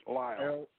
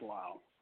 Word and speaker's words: Lyell: Yes, Lyle. – L-Y-E-L-L? – Mm-hmm. It's pronounced Lyle.